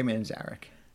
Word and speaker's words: Manzarek: Yeah, Ray Manzarek.